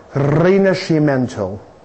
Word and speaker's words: Rinascimento: Michelangelo in Italy，啊，这回懂了，教我这个词的念法叫做 Rinascimento。